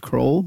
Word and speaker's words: Kroell: Kroll. My last name is spelled Kroell.